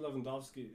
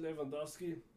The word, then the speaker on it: Lewandowski: You know, they say instead of we in Germany, we say Robert Lewandowski. Americans tend to say Robert Lewandowski. Or they, but I believe in Polish, you would say Robert. No, wait, hold on. How would you say it? Robert Lewandowski. Lewandowski, kind of like Russian.